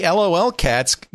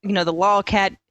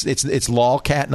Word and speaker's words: lolcat: So, everyone kind of laughs about, the lolcat. Phenomenon. – I'm sorry. Did I say I said it wrong? It's lolcat, not LOL cats. – I say lolcat.